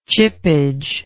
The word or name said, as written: chippage